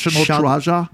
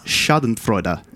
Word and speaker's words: Schadenfreude: It's pronounced National Traja? – No. – Oh. – It's pronounced Schadenfreude. It's German.